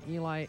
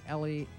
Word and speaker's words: Eli: And Eli, Ellie, it's spelled E-L-I. I know two different people who have the same spelling, just pronounce it differently.